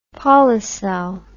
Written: Polycell

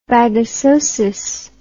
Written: bagassosis

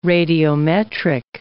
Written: radiometric

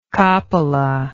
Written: coppola

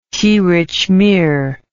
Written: tirichmir

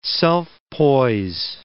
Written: self-poise

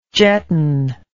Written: jetton

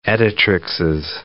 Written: editrixes

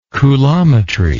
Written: coulometrically

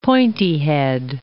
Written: pointy-head

